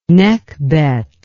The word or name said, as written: Nekhbet